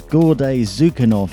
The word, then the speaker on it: Gordey Tsukanov: Cliffy's put the way to pronounce the previous producer's name into the chat room, spelt phonetically, so I can actually pronounce it. Apparently his name is Gordey Tsukanov.